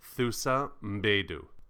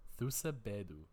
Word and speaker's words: Thuso Mbedu: I'm definitely completely not pronouncing her name right. – Thuso Mbedu. – Thuso Mbedu. Okay, I was so far away.